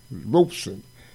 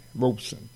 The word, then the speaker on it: Robeson: And he was particular about you pronouncing his last name right, Robeson. It's R-O-B-E, like a robe, rope, and then son, Robeson.